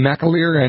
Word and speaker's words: McAleer: McAleer and Anne McElhaney.